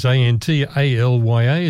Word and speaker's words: Antalya: I've got it phonetically pronounced here, so I don't muck it up. I looked it up on the web. How do you pronounce this? It's Antalya.